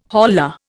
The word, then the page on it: holla: Indian